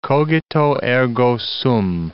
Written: cogito, ergo sum

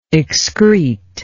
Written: excretive

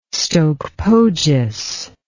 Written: stoke poges

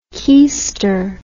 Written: keyster

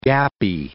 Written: gappy

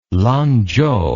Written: lanzhou

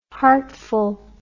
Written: heartful

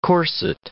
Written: corset

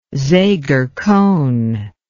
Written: seger cone

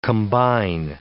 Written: combine

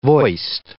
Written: voiced

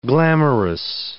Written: glamourous

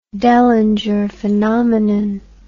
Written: Dellinger phenomenon

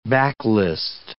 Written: backlist

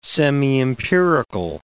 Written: semiempirical

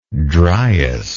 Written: dryas

